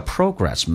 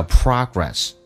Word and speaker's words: progress: A country can only make progress，英式发音叫 progress，美式发音叫 progress，所以那个 o 发成 r 这个发音，进步。